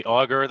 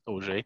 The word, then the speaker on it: Auger: Hello, my name is Sebastian Auger. You can say Auger, that's fine too, but it's actually pronounced Auger.